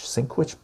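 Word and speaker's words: Sienkiewicz: Someone said how to pronounce this. Sinkwich. Bill Sinkwich.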